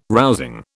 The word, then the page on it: rousing: British English